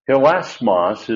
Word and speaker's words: hilasmos: Hilasmos is the first one, H-I-L-A-S-M-O-S. That little comma that's above the iota, for those who are looking in the bulletin, is a rough breathing mark. That's why we pronounce it hit. It's hit. That's a rough breathing mark. If it didn't have that, it would be it.